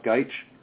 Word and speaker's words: Gajic: His last name is spelled G-A-J-I-C. It's actually pronounced Geich.